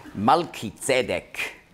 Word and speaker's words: Malki-Zedek: Melchi, auf Hebräisch spricht man aus Malki-Zedek, König der Gerechtigkeit.